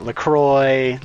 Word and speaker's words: LaCroix: The Crawl is my favorite brand of sparkling water. That's how you actually pronounce that. You know, everyone argues. – What? – Is it LaCroix? – Okay, that's pretty good.